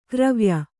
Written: ಗರವೊಯ್

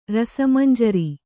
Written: ರಸಮಂಜರಿ

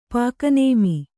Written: ಪಾಕನೇಮಿ

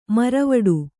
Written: ಮರವಡು

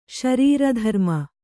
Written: ಶರೀರಧರ್ಮ

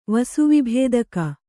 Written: ವಸುವಿಭೇದಕ

